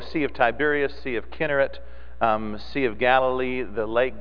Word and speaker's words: Chinnereth: Or in your King James and in the English Bible, it's spelled C-H-I-N-N-E-R-E-T-H. But we would look at that and say Chinnereth, but probably better, closer to the Hebrew, is Kinneret.